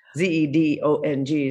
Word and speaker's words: Zedong: They say with a Z, like it sounds like a Z. Zedong is the pronunciation, as I understand it.